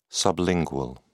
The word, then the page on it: sublingual: British English